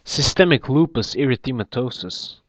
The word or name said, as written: Systemic lupus erythematosus